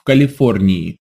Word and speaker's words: Калифорнии: Да, я живу в Калифорнии. – Instead of the expected yeah sound, we just get a long E.